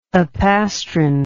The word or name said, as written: apastron